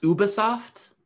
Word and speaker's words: Ubisoft: So an open world Star Wars game coming up from it's pronounced Ubisoft. – Ubisoft. – Ubisoft. I always thought it was Ubisoft. – I believe it's Ubisoft. – No, I think you're right.